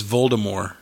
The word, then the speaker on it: Voldemort: I read the other day that J.K. Rowling said the correct pronunciation Voldemort. – Is Voldemort. – Yeah. – Mm-hmm. – Mind blown. – How do you make the T silent is my question.